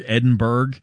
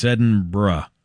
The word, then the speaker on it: Edinburgh: They're performing in Edinburgh, Scotland, which breaks my American brain to say, because it looks like Edinburgh, and that's how I want to pronounce it, like Pittsburgh, Edinburgh, but no, it's Edinburgh, which feels wrong.